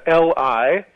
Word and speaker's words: Li: Li Yusi is L-I, which is the nearest approximation that Gui Zhen gave him his name, I should say. So Li is like the first syllable of Needham.